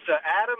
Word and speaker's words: Adam: Well, it's Adam Niemerg, actually.